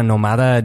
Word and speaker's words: nómada: estamos hablando sobre una clase de persona que se llama una nómada digital o un nómada digital. Mm, nómada. – Nómada, ah, con el acento en la primera O.